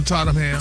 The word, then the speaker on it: Tottenham: He'll perform at Tottenham Hotspur. Is that how you say it? – Uh-huh. Tottenham.